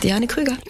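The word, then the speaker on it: Diane Kruger: Also hallo liebe Hörerinnen und Hörer, hier ist Diane Krüger.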